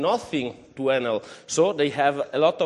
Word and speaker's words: Enel: Enel is a I think in Italy, in Italian, we pronunciate it Enel.